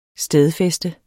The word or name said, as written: stedfæste